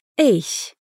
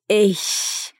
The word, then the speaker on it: ich: No Hochdeutsch se diz ich, como se fosse um gato bravo, sabe? Ich. Já no Berlinerisch se diz ich, com o som de K no fim da palavra, ich.